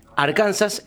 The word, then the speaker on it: Arkansas: Pero fíjate que en Arkansas, que es otro sitio, recién dijimos Missouri, Arkansas, lo pronuncian Arkansas, porque está afrancesado, digamos, o francofonizado, y viste que los franceses le sacan la S a todo al final.